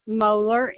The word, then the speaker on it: Moeller: Deborah Moeller, M-O-E-L-L-E-R. – Spell that one more time for me. I'm sorry. – M-O-E-L-L-E-R.